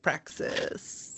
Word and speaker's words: praxis: Might even say praxis. – One might, but I would only ever say it like praxis. – Yeah, that's right. That's the correct pronunciation.